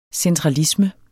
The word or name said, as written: centralisme